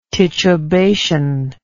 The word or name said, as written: titubation